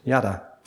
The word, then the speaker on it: yada: And I even looked up on Google how to pronounce it, and it's yada. So it's pretty good.